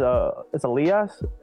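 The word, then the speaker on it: Elías: And like with my name, like it's Elias in Spanish, it's Elias in English, and like a lot of people get that wrong, and I'm like, it's perfectly fine.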